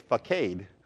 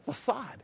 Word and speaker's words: facade: F-A-S? No, C-A-D-E. And if I was going to look at that word, I'd say it's facade, but it's pronounced facade.